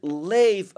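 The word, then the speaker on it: lev (לב): One is the word lev, and it's spelled lamed vet.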